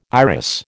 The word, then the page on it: iris: American English